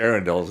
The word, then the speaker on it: Arundel: Well, we're broadcasting this week from the Shady Grove RV Park right here in, is it Arundel? – They pronounce it Arundel.